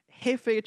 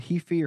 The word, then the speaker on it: Hefei: I think it's called Hefei, or it's spelled H-E-F-E-I.